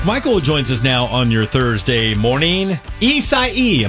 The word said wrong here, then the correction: Isaias: am I saying it right? – Almost. You forgot the final S at the end. – What is it? Isaias? – Isaias.